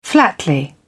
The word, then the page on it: flatly: British English